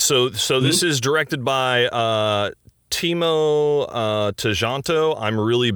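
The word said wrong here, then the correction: Tjahjanto: It's Chancho. – Oh, cool. – Yeah, it's a CH sound with the T and the J there.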